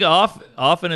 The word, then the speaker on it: often: I think often is the correct way. – There's a T in there, isn't there? – Yeah, it's a silent T.